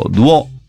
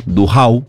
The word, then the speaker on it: Duhau: como es el Palacio Duó, o el Palacio Duhau, como le dicen a aquellos que evitan la pronunciación en francés,